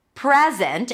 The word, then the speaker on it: present: the secret is in the stress. Present, if the stress is on the first syllable, it's a noun. I want to give you a present. Present. If it's a verb, the stress is going to be on the second syllable, present.